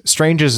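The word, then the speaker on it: Stranges: Strange's is the right way to say it, right? – Strange's. – Yeah. – It's not like strangers. It's more like a soft A.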